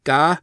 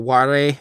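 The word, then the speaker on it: Ga (我): which is pronounced uh, Ga, but would normally be read as uh, Ware. It's like an archaic uh, personal pronoun for I, um, like me, the self.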